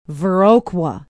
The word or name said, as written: viroqua